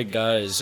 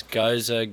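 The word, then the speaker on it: Gose: Assam boy goes. There's a lot of ways to pronounce goes. Goza, goes. There's no wrong answers in my opinion.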